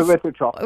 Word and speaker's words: erythritol: What, malitol, erythritol. Is that how you pronounce it? I think, mm-hmm. – Erythritol. – Erythritol.